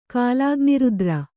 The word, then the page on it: ಕಾಲಾಗ್ನಿರುದ್ರ: kālāgnirudra